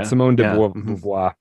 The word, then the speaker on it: Simone de Beauvoir: Simone de Beauvoir.